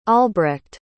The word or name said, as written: albrecht